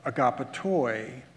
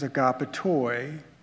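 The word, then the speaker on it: agapetoi (ἀγαπητοί): Beloved is similar and has agape at the root and is pronounced agapetoi. Agape versus agapetoi.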